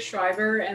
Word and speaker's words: Szrejber: My name's Andrea Szrejber, and the way it's spelled, you probably never know, that's how you pronounce it.